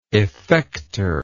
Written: effecter

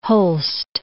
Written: Holst

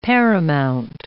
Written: paramount

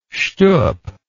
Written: schtup

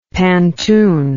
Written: pantun

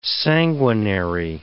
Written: sanguinary

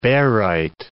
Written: barite

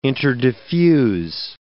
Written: interdiffuse

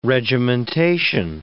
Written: regimentation

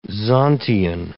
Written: Zontian

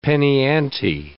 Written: penny-ante

